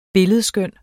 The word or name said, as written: billedskøn